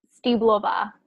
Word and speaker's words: Stýblová: Is that how you say your name? Jana Stýblová? – Stýblová.